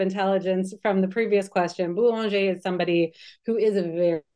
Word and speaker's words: Boulanger: Is that how you pronounce it? – George Boulanger.